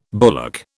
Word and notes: bullock: British English

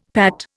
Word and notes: Pat: Indian

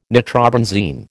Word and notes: nitrobenzene: American English

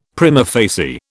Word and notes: prima facie: British English

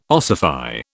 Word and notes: ossify: British English

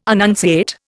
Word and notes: annunciate: Indian

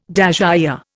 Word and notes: dasyure: Indian

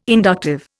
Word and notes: inductive: Indian

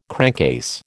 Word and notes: crankcase: American English

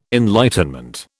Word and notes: enlightenment: British English

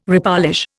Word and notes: repolish: Indian

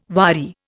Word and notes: wadi: Indian

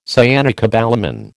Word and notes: cyanocobalamin: American English

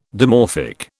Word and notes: dimorphic: British English

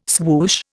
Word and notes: swoosh: Indian

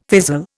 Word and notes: fizzle: Indian

